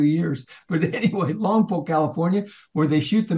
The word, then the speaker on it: Lompoc: And Paul is in the thriving city there of Lompoc, and you got to say it like Polk, like the Cowboys.